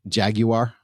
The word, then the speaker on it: Jaguar: Jaguar. – Well, that's how the Brits say it. They say Jaguar.